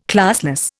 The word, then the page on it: classless: Indian